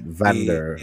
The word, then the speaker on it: Van'Dre: I pronounce it Vander. – A-N-D-R-R.